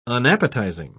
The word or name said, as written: unappetizing